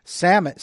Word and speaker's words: salmon: Salmon, Salmon, Salmon, it's probably pronounced Salmon in the Hebrew, but the fish is pronounced salmon. Leave the L out of the word salmon when you're speaking of the fish.